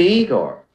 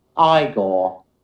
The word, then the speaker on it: Igor: You must be Igor. – No, it's pronounced Igor. – But they told me it was Igor. – Well, they were wrong then, weren't they? – Attention, everybody.